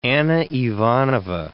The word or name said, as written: Anna Ivanovna